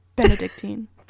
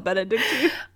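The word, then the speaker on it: Bénédictine: I was telling myself it's not Benedict. It's not that. Okay, so Benedictine.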